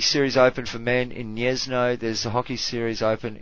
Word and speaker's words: Gniezno: Is it Niez Niezno? Gniezno. There you go.